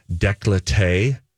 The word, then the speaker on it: décolleté: I know someone who had like their whole like décolleté tattooed, like a spider web.